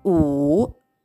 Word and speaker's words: 五: そして最後、ごはですね、うー、うーと発音します。 wu と書きます。えー、と、成長は第 3 世ですので、低く沈む音になります。うーなんですが、えー、日本語の「う」よりももっと口を細めて唇を細めて鋭く「う」というふうに発音します。